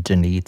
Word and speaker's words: Denis: I'm going to go with his Canadian pronunciation, Denis, today.